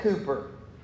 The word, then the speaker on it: Cowper: One of our great writers of our hymns, William, it's spelled Cowper, but it said Cooper.